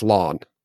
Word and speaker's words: Lan: It doesn't rhyme with man. So I assumed they meant it was pronounced lawn.